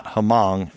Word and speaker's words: Hmong: It's spelled H-M-O-N-G, so that's not Hmong, it's Hmong. – That's correct.